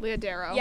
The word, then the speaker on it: Leah Darrow: And they don't tell women the other side of the story, where it's, like, you know, I think it's, what is it, Leah Dara? Is that how you say her name? – Leah Darrow.